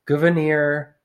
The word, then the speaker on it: Gouverneur: It looks like governor, but it's pronounced gouverneur.